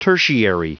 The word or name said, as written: tertiary